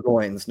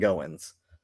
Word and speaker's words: Goines: It is Parker Goines, not Goins. Goins, like coins.